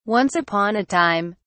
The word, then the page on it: once upon a time: British English